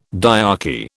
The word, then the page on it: diarchy: British English